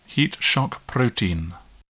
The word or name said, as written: heat shock protein